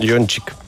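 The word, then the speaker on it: Jochnick: Afionic. am pronunțat corect? Ioncic. Ioncic, așa este fondatorul uh, brandului Oriflame.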